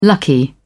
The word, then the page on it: lucky: British English